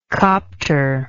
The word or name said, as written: copter